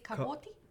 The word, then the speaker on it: Quixote: Now, it's spelt a particular way, but the pronunciation is Quixote.